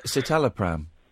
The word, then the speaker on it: citalopram: I was on, I can't even pronounce the name of the thing, the cytoflam or cyto, whatever it is. – Citalopram.